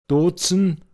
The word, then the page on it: Gatzlmåcha: Pinzgauer Mundart